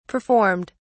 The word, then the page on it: performed: British English